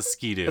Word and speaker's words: Ski-Doo: What is a ski-doo? – A ski-doo. Am I saying it wrong? No, you're saying it right.